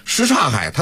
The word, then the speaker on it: 什刹海: 生活中啊，在北京的土话当中有很多都是这种情况，他明明这个字儿，比如说正确的发音是这个，但是呢因为都习惯了，你比如说这个什刹海，是吧，什刹海他就是正确的读音什刹海，但是老北京你说叫什刹海的不多，都是什刹海，是吧，都说什刹海，